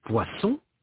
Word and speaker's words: poisson: Un poisson, un poison. Un dessert, un désert. – Notez la différence de prononciation. Il ne faut pas confondre poisson et poison.